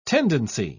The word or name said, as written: tendency